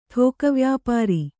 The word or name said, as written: ಠೋಕವ್ಯಾಪಾರಿ